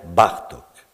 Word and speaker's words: Bartók: L'accent tonique ne se place pas comme dans la langue française, il se place bien plus volontiers sur le, la première syllabe du mot. On dira Bella Bartok.